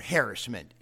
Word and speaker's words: harassment: King Jesus was willing to suffer harassment or harassment, as they tell me it should be pronounced.